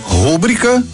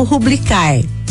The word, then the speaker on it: rubrica: Na língua portuguesa temos rubrica substantivo e rubrica do verbo rubricar. Em ambos os casos, a forma correta é rubrica. Rubrica é uma palavra paroxítona. Tem a penúltima sílaba tônica, nesse caso, bri, rubrica. Tem documento que você precisa assinar por extenso e em algum documento você pode apenas utilizar a rubrica.